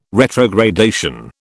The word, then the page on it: retrogradation: British English